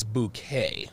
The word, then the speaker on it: Bouquet: Yeah, so this woman's name was her last name was Bucket, but she told everyone her last name was Bouquet, right? Like the E and the T is silent.